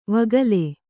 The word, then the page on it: ವಕೀಲಿ: vakīli